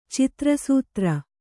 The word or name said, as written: ಚಿತ್ರಸೂತ್ರ